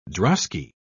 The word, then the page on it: drosky: British English